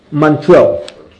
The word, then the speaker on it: Montreux: But Avram Abba, and I have to tell you, Eitz Chaim Yeshiva of Switzerland, of Montre, am I pronouncing it right? – Montreux.